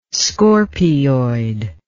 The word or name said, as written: scorpioid